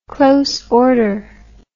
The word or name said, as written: close order